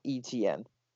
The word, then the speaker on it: Etienne: Travis Etienne. – Etienne, I don't know how to pronounce his last name, but. – Yeah, Travis Etienne. It's literally just Etienne.